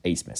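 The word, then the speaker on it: Abmas: And hopefully, if you're listening to this podcast, you know about Max Asmus, and you know that the B is pronounced Asmus somehow.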